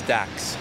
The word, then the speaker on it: Dax: My name is Dax. – How do you spell it? – D-A-X.